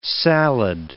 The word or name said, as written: salad